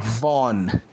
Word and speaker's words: Vaughan: É, só para vocês saberem, o, o, sobren o sobrenome do, do autor de saga é Brian K. Vaughan. Porque a sílaba AU, AW, AL ou AWE em inglês normalmente é pronunciada como O. E o GH, muitas vezes no inglês, ele é mudo então, isso faz com que o sobrenome desse autor seja pronunciado como V-O, acento no O-N, Von.